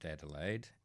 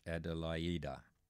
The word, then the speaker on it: Adelaide: Beethoven's Aida, Adelaida, not pronounced Adelaide, but pronounced Adelaida, Opus 46, with Frederick Schoecker at the piano, and the tenor was, if you didn't guess, Jussi Björling.